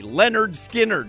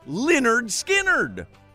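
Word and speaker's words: Lynyrd Skynyrd: In fact, a lot of people think it's one guy, Leonard Skinner.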